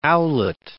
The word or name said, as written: owlet